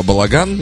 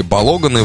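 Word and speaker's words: Balogun: Вот у них два мяча забил во встрече с Парагваем Балаган или Балоган его, я не знаю, как правильно ударение. По-моему, я его Балаган всю жизнь называю. Но он молодой достаточно. – Первый вариант. – Да, Балаган пойдет.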